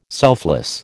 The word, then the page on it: selfless: American English